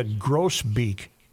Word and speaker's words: grosbeak: The bird you have been talking about is a grosbeak, not a grasbeak.